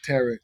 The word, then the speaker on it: tarot: Afterwards, I want you to text that word Akashic Tarot. – Okay. – Tarot. Well, we say tarot. – We don't pronounce the T. Yeah, that's right.